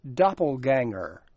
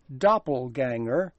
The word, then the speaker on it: doppelganger: 形容这种长得一模一样的人，英语里还有一个特别的词，叫做 Doppelganger. Doppelganger is spelled D-O-P-P-E-L-G-A-N-G-E-R.